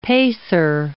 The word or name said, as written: pacer